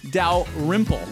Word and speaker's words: Dalrymple: I felt like the emphasis was on the wrong syllable. – I said it correctly. That's why. – Dalrymple? – Dalrymple. Like Rimple Stillskin.